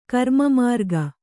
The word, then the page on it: ಗ್ರಾಮಮೃಗ: grāma mřga